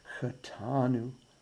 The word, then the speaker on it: Chatanu: I think it helps emphasize the penitential nature of this section by putting just a tiny little bit of pressure on the second syllable of those three words. Chatanu, Avinu, Pashanu.